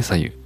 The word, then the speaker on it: 白湯: えー、左右は漢字で白いお湯と書いて左右。